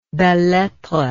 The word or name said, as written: belles lettres